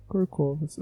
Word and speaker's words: corcovas: Duas, duas, dois, duas corcovas ou córcovas. Não tenho certeza como é que fala. É, enfim, ele tem duas, duas córcovas. Não, deixa eu ver aqui, eu vou pesquisar. Corcovas. Corcova. Ah, mas é corcovas, porra. É corcovas.